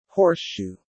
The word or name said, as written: horseshoe